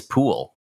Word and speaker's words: Pwyll: And their name is Pool, which is spelled P-W-Y-L-L. It's a Welsh name.